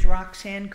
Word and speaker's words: Roxanne: My name is Roxanne Grunheide, And it's spelled R-O-X-A...